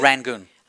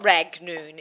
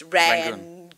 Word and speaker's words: Rangoon: How do you say Rangoon? – Rangoon. – Okay, not Ragnoon. It's Rangoon.